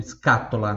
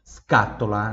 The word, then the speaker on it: scatola: Então, por exemplo, começando com A, SC seguido de A, a pronúncia fica igual da língua portuguesa. Então, por exemplo, escátola, né? Que é caixa, escátola, né? Se escreve S-C-A-T-O-L-A, escátola, escátola.